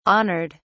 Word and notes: honored: British English

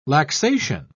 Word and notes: laxation: British English

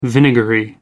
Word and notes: vinegary: American English

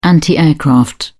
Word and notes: anti aircraft: British English

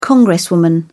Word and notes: congresswoman: British English

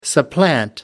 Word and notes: supplant: American English

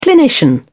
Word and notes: clinician: British English

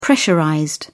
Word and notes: pressurized: British English

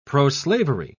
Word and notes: proslavery: British English

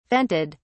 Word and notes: vented: British English

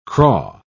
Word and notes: craw: British English

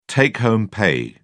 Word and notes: take-home pay: British English

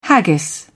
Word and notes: haggis: British English

